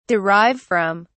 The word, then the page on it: derive from: British English